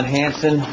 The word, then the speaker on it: Hansen: My name is John Hansen. Last name is spelled H-A-N-S-E-N.